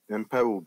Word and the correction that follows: Mwepu: I think it's Mwepu.